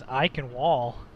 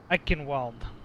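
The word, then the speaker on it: Eichenwalde: My best pronunciation was Eichenwall, but it's not at all pronounced like that.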